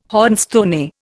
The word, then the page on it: hornstone: Indian